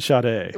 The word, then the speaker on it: Sade: Sade? Xbox One Sade? – It's the Xbox One Sade.